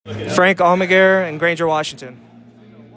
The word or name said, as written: Frank Almaguer